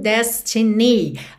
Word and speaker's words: destiny: Normalmente a gente teria destiny. Destiny, a sílaba tônica é a primeira. Mas aqui, por conta da melodia, a gente tem destiny. Então, ni acabou ficando mais forte, ok?